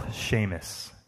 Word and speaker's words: Seamus: where he would celebrate his beloved Uncle Seamus and his 80th birthday.